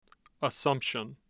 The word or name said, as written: assumption